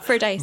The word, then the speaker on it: Fordyce: Is that how you pronounce it? – Yeah. – Fordyce? – Yeah. – Fordyce? – Fordyce? No, it's Fordyce, that yeah.